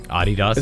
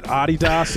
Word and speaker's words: Adidas: This is like the people who are annoying about the pronunciation of Adidas. – Adidas? – Is that Adidas? Is that what they say? – I just thought that was a funny way to pronounce it. I always just said Adidas to be, like, quirky.